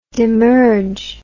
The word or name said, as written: demerge